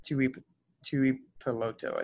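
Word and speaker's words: Tuipulotu: I think it's Tui Peloto, I think. – Yeah, you got it.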